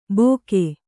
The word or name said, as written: ಬೋಕೆ